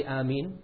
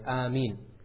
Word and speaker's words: Ameen: By the way, the proper way to say Ameen is as I am saying it, Ameen. Many people, they say Ameen, that's not correct. The first alif should be two harakas, Ameen, that's it.